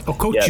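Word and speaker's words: Okhotiuk: Go ahead and say his name for me. – Okuchuk. Yep, there we go.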